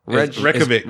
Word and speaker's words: Reykjavik: Revnik, what's the capital of Iceland? – Reykjavik.